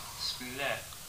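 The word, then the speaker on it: Splet: Split. Split. Split. – Split. Split. Oh, Alan R. split.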